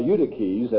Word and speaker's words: Eutyches: 449, Eutychius, well, Eutyches, as he should be pronounced, Eutychianism, Eutyches, he was a rascal.